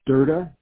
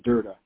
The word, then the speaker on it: Derda: And my name is spelled D-A-N-N. That's the first name. And the last name is Durda, D-E-R-D-A. So it's D-A-N-N, first name, last name, D-E-R-D-A, Durda.